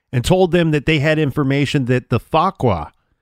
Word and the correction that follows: Fuqra: And I'm not saying that right. F-U-Q-R-A, Fakra.